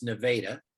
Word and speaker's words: Nevada: We chatted for a moment ahead of time, and you said you're in Missouri, in a town called Nevada, which is spelled like Nevada, but pronounced Nevada.